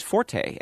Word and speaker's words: forte: But in the sense of one's strong point, you know, his forte is you know, that is really more commonly pronounced as forte. And so you're perfectly correct to say forte. We give both pronunciations in the dictionary, but we do note that the sense of one's strong point is usually forte.